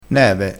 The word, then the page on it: neve: dialettale